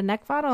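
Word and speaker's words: Neckvatal: You can also find me on LinkedIn just using my name, which is Brenda Neckvatel, and that's spelled N-E-C-K, V as in Victor, A-T-A-L.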